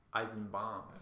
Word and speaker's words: Eisenbaum: Did I say her name right? Is it Eisenbaum? Eisenbaum. – Again, that's how I say it.